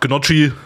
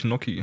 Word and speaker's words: Gnocchi: Der Deutsche sagt Gnocchi, aber ich glaube, richtig ausgesprochen wird es, glaube ich, Gnocchi.